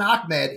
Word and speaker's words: Ahmed: I was saying it wrong. I said Ahmed, but it's ah, you got to get the ah in there, like Akbar ah, yeah.